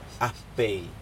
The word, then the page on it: apei: Aneityum